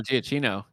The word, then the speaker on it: Giacchino: Oh, Giacchino.